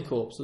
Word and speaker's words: corps: Like zombies, which would be a very interesting turn of events, as the word for body in French is corps, pronounced corps.